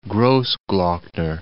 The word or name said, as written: Grossglockner